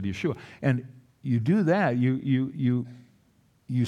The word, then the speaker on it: Yeshua: The word for salvation is Yeshua. Yeshua is the Hebrew pronunciation of the word Jesus.